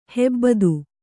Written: ಹೂಬತ್ತಿ

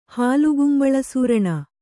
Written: ಹಾಲುಗುಂಬಳಸೂರಣ

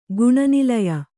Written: ಗುಣನಿಲಯೆ

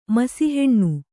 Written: ಮಸಿಹೆಣ್ಣು

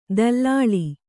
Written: ದಲ್ಲಾಳ

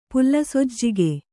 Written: ಪುಲ್ಲಸೊಜ್ಜಿಗೆ

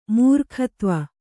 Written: ಮೂರ್ಖತ್ವ

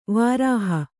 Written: ವಿರಹ

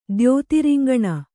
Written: ದ್ಯೋತಿರಿಂಗಣ

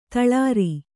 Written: ತಳಿರು

